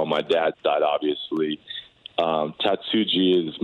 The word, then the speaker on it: Tatsuji: So I want to, it's Tatsuji, that part is the J we pronounce. Okay. – We pronounce the J.